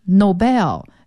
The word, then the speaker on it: Nobel: 熟练的识别或者是去到运用的程度，还是需要大量的去建句子。那么在今天要听的这个材料中呢，还有比较常见的一些专有名词，比如说诺贝尔奖金这个词 Nobel N-O-B-E-L，那你查字典呢，它会把重音放在 bell 上，是 The Nobel Prize，就是诺贝尔奖这个词。当然了，按照英文的这个读音规则呢，也有的人读成 Nobel，把重音放前面。